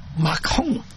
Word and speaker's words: Macron: 佢个法文名系咁发音嘅，我之前上网听叫 Emmanuel Macron，所以正台湾嘅翻译就比较正常，叫马克红。因为咧佢唔系读龙嘅，佢因为 R 音嘅发空嘅音噶嘛，所以叫马空，所以呢个马克红会好啲咯。